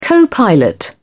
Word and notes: co-pilot: British English

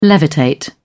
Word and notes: levitate: British English

